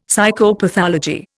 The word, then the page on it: Psychopathology: Indian